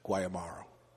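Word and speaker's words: Guáimaro: G-U-A, but there's a tilde, the accent mark over the A, I-M-A-R-O, so Guayamaro. – Almost like the shirt? – Guayabera, Guantanamo, Guayamaro.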